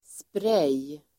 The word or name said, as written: sprej